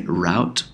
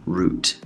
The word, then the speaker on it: route: 简单的翻译一下，你能不能推荐一下一个好的、挺流行的、挺火的路线？Um, so could you 就是能不能，and then recommend recommend 就是建议、推荐。Popular means 火、流行。um, 著名的 like that and then route so you can say route or route both are okay